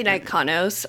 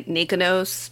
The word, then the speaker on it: Nikonos: I say Nikonos. A lot of people say Nikonos.